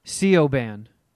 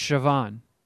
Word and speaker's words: Siobhan: See, it looks like you would phonetically pronounce it Sioban, but it's Siobhan.